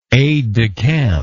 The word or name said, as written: aid de camp